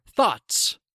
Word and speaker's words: though: So we must thaw our thoughts. Thaw our thoughts. Thaw thoughts. Thaw those thoughts. Number two, though. English is fun, though it can be difficult to learn sometimes. Though. Hmm. Though rhymes with grow.